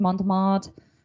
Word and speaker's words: Montmartre: So we'll start with the Monster of Montmartre. Did I say that right? – Oui. – Oui. Okay. So he had a few nicknames. Monster of Montmartre.